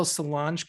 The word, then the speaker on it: Solange: I went on a little Solange kick the other day.